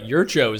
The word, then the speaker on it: Jurčo: Like, no one else could score on that team, even though they had Tomáš Jurčo. Or it seems like Jurčo is the actual way to pronounce it based on the commentators who were pretty well informed there.